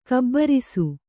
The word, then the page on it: ಘಾಬರಿಸು: kannada